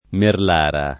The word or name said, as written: Merlara